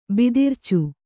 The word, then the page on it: ಬಿದಿರ್ಚು: bidircu